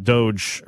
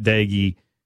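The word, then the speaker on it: Doege: Even though Micah Johnson found out it's pronounced Daigie, not Doge, earlier this week with Britton Gray.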